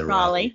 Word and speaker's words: Raleigh: Raleigh, yes.